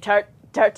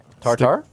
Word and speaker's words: tartare: He was the steak tartare. How do you say it? – Tartare? – No. – Tartare. – Thank you.